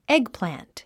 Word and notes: eggplant: égplæ̀nt; エグプラント

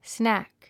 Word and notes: snack: snǽk; スナァック

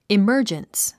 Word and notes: emergence: iməːrdʒəns; イマァジャンス

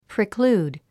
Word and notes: preclude: priklúːd; プリクルード

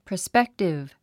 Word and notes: prospective: prəspéktiv; プロスペックティブ

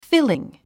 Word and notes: filling: fíliŋ; フィリン

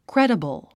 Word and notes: credible: krédəbl; クレデェボゥ

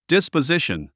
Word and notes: disposition: dìspəzíʃən; ディスポジション

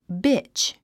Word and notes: bitch: bítʃ; ビチ

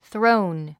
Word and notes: throne: θróun; スローン

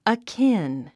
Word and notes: akin: əkín; アキン

